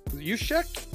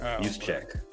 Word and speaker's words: Juszczyk: Juszczyk? – Juszczyk.